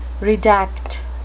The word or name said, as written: redact